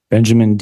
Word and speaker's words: Benjamin: Benjamin Dean, B-E-N-J-A-M-I-N-D-E-A-N.